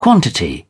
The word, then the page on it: quantity: British English